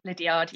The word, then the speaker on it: Liddiard: Liddiard, yeah.